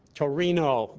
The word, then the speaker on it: Torino: Torino, they pronounce it in Italy. Right, Victoria? Torino. Am I right on that, how they pronounce it in Italy? We pronounce it Turin.